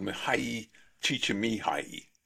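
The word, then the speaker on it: Mihaly Csikszentmihalyi: And there is a professor, University of Chicago, he's Hungarian and he has a totally unpronounceable name called Mihai Csikszentmihalyi. I think that's how you say it. – That's pretty close, and it's a tough one to say.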